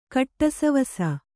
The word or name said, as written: ಕಟ್ಟಸವಸ